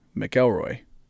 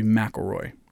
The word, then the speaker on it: McElroy: But the English language is actually M-C and then a consonant. It's pronounced Mick, just like McDonald's, right? Because it's M-C-D. But if it's M-C and then a vowel, like my last name, it would be McElroy. So it'd be a Mac.